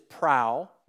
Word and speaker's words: prau: The Greek root is prau. We would spell it P-R-A-U.